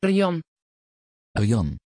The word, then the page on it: Rian: Dutch